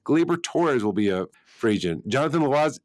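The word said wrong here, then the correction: Loaisiga: I, Lo, I can never pronounce Loizaga.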